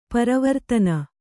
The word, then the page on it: ಪರವರ್ತನ: Kannada